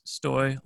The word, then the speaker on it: Stoj: My friends started to chant Stoj Boy when I was competing in different sporting events, and so my name spelled Stoj but pronounced Stoj like boy.